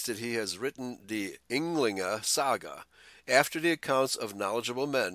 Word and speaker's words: Ynglinga saga: In the Ynglinga saga, did I pronounce that correct? Ynglinga? – Ynglinga saga.